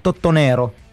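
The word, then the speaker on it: Totonero: Το λεγόμενο Τοτονέρο. Το λεγόμενο Τσιταλικά, αν το πεις. Έτσι είναι η προφορά.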